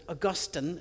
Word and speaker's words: Augustine: And that's when Augustine, most of you would call him Augustine because he has an E on the end of his name, but he would have pronounced it Augustine of Hippo,